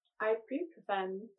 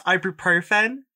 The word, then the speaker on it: ibuprofen: Can you pronounce that again for me? – Yeah. – I think what you mean is ibuprofen.